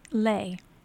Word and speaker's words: lei: It's actually lei. L-E-I-S. – L-E-I-S. I've never heard of lei. – The singular is L-E-U.